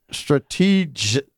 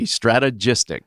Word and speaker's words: Strategystix: Strategic. No. no emphasis on the G, strategystix. Strategystix. Okay.